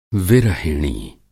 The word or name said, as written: विरहिणी